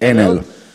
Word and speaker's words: Enel: Enel is a I think in Italian we pronunciate it Enel.